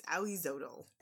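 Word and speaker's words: Ahuizotl: I wrote it out phonetically so I could remember. It's Oizotl.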